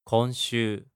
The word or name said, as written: konshuu